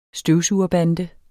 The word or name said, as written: støvsugerbande